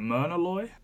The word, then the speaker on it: Myrna Loy: even footing, and like she gives as good as she gets, and I think, how do you pronounce her name, is it Myrna Loy? – Yes